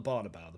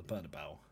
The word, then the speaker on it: Bernabeu: Not the Barnabeu, the Bernabeu. – The Bernabeu, Barnabeu. It depends where you come from, really, and how you say it.